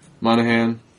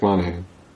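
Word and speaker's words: Monaghan: It stars Hugh Jackman, Liev Schreiber, Danny Houston, Will.i.am, Lynn Collins, Kevin Durand, Dominic Mahogany, Monahan, in my head, silent G, and I just said the G anyway.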